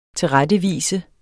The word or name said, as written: tilrettevise